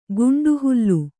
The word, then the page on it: ಗುಂಡುಹುಲ್ಲು: guṇḍuhullu